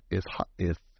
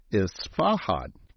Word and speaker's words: Isfahan: Lockheed C-140 Jetstar crashed in Isfahan, Iran. Hey, you guys got to get a kick out of the way I pronounce some of this shit. It's Isfahan. Isfahan. Killing 18.